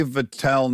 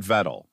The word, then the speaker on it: Vettel: For the record, you say Vettel, not Vettel.